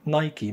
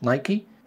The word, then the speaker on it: Nike: طيب سؤال هنا، هو يحصل حاجة لو نطقتها نايك مش نايكي؟ الحقيقة هو المفروض مش اختياري، المفروض انك تنطق الحاجة صح وما تعدلش على اسمها. النطق الصحيح ليها هو نايكي، لان الشركة اتسمت على اسم الاسطورة اليونانية القديمة لما يطلقوا عليه عندهم آلهة النصر. إلهة النصر عندهم اسمها باليونانية نايكي، فالشركة اتسمت على اسمها، ولكن لأن ناس كتير جدا بقت بتنطق الاسم غلط ما بقاش حد بيدق قوي انت بتقول نايكي ولا نايك.